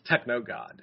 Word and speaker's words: TKNOGD: The literal spelling is T-K-N-O-G-D. But when pronounced phonetically, it's Techno God.